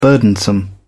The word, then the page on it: burdensome: British English